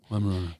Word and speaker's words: Weimaraner: This Weimaraner that I adopted. When am I saying it right? – Yeah. – Is that how you say it? – Weimaraner.